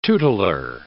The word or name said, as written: tutelar